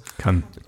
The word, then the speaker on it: Cannes: Also jetzt nicht so ganz krass wie die Filmfestspiele in Cannes oder so, aber spricht man so aus? – Kann. – Aber Cannes war schon. – In Cannes. Aber mir gefällt, dass du es versucht hast.